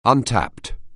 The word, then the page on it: untapped: anglais